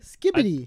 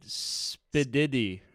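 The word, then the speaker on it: skibidi: Oh, there is comments on the Britney app that I mispronounced skibbity. – Yeah. – I thought I got that right. – Skibbity. – I think you said spididdy. – No. – You swapped the B and the D.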